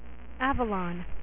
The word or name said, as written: Avalon